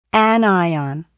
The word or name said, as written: anion